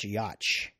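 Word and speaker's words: Jocz: It was actually directed by Steve Yoch, I think, J-O-C-Z. I think his name is pronounced Yoch, but the drummer for Sum 41.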